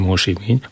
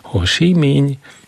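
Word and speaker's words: Ho Si Minh: Ezt egy afrikai püspök barátomtól tanultam meg, hogy így kell kiejteni, mert én mondtam, hogy Hosimény, azt mondja, nem, az nem Hosimény. Hosimény.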